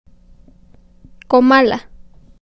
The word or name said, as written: Comala